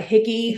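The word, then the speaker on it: hygge: When you had to pronounce it, it's like Higgy.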